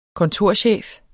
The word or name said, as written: kontorchef